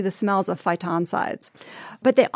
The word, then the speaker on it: phytoncides: At one point on this journey, you breathe in, am I saying this right, phytoncides? – Yes.